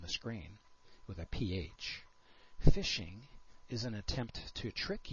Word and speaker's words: phishing: And as I mentioned, this is pronounced like the word phishing, except for in this case, the attacker is phishing for information about you.